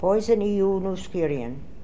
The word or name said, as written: Poisonioonoskirian